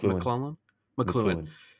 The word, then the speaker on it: McLuhan: And I wonder if McClellan, or am I pronouncing his name right? McLuhan. – Yeah.